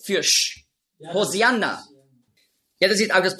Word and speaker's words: Hosianna: Hosianna ist einfach die griechische Aussprache von Hoshi'anna. Hosianna, das ist die griechische Aussprache. Weil die Griechen, die haben im Alphabet kein Sch-Laut. Nur Z.